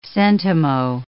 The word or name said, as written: centimo